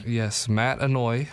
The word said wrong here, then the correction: Anoa'i: Anoa'i, sorry. Yes, those Samoan names are difficult to pronounce.